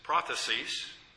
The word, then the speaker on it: prothesis: And that first is prophecies, with the accent on the first syllable.